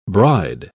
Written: bride

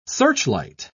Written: searchlight

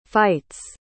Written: fights

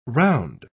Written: round

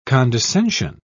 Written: condescension

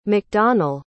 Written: macdonell

